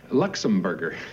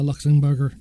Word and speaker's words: Luxembourger: I've met many of our allies over here, sir, but you're the first Luxembourger. – That's actually real. – Yeah. Although this is done for comic effect, it is actually the correct way to pronounce it. – I am a Luxembourger.